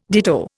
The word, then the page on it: ditto: Indian